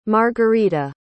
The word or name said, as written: margerita